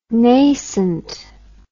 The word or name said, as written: naissant